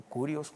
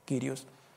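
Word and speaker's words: Kyrios (κύριος): Viene del griego Kyrios o Kurios, Kyrios, en el griego la pronunciación creo más correcta, Kyrios.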